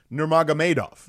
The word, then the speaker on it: Nurmagomedov: There are a lot of people that never try to say his last name, and it's Nurmagomedov. Yeah, that's how you say it, Nurmagomedov.